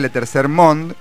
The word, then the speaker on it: Le Tercer Monde: Somos Le Tercer Monde, Pequeño Burgués Ilustrado, que se escribe Le Tercer Monde, pero se pronuncia Le Tercer Monde.